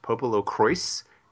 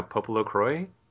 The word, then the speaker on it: Popolocrois: The official word is that it's pronounced Popolocrois, but it looks like a French word to me. Yeah, like they pronounce the S in the game. – That's obnoxious. – So I don't know, Popolocroy is what it sounds like to me. But I'll say Popolocrois just because that's how they say it in the game.